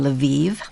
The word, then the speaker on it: Lviv: She had a semester at the Catholic University of Ukraine in Lviv. That's how she pronounced it, so that's how I pronounce it, which is in the western part of Ukraine.